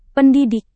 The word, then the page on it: pendidik: Indonesian